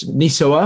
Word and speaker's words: NISOA: And I know we have a lot of Nis- NISOA, I'm saying it correctly this time, NISOA officials on the group.